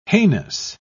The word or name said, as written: heinous